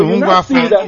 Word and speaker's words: Ngwafor: And if you check this name, Ngwafo, the way she pronounced her own was Ngwafo something. The way, if I can remember vividly, the spelling is the same way Igbo spell our own. The spelling is N-G-W-A-F-O-R.